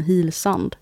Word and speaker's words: Hillsand: För det är ju det enda sättet, man kan ju inte uttala hilsand på något annat sätt än Hillsand.